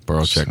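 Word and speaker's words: Borowczyk: directed by Valerian Borochek. I believe that's how you say his name anyway, Borochek. – Sounds good to me.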